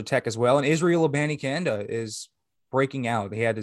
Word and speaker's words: Abanikanda: Yeah, it's Israel Abanikanda. Yep, that's how you say it, Abanikanda.